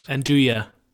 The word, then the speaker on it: Nduja: Just to, there was some nuja, is that, am I pronouncing that right? – Nduja.